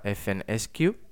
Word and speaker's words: FNSKU: Esto puede que te suene de anteriormente, hace unos, momentos, que estábamos hablando del FNSKU, que también se pronuncia FNSQ, que es el Fulfillment Network Stock Keeping Unit.